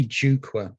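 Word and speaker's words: Jukwaa: So obviously, this all kind of leads up to what you're doing currently, and that's Jukwa, isn't it? Is that pronounced right? – Nearly right. And it's quite often pronounced like that, but it's really Jukwa, and it means platform in Swahili.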